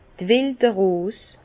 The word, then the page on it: d' welde Rose: Bas Rhin; Herrlisheim